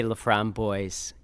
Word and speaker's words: LaFromboise: We say LaFromboise, okay. – You know, but... – the S. – Yeah, we say the S. Obviously, that's... Not the French pronunciation.